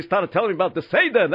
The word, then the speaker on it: Seder: No, it's pronounced Seder.